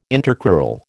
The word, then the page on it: intercrural: American English